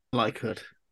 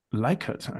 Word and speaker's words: Likert: Did I pronounce that correctly or is it Likert? I never know. – Likert. – Likert. Okay, damn. I've been saying it wrong.